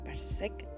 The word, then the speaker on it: Berserk: Berserk se dice así, así.